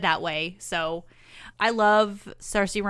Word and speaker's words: Saoirse: And all the while, he is also romancing a girl named Agatha, played by Saoirse Ronan. – Is that how you say her name? Mm-hmm. I always thought it was Sorsa, but I don't think I've ever heard anyone say it until today.